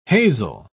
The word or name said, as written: hazel